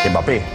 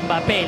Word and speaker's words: Mbappé: Mbappé le ha pintado la cara al Barça. – Vale, pero no se pronuncia Mbappé, la, la M es muda.